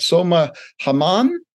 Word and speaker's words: Hammam: Uh, and uh, uh, you know, uh, help me if I pronounce it wrong, but soma Hammam. – Yeah, that's perfect.